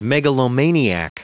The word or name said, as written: megalomaniac